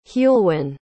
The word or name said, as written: heulwen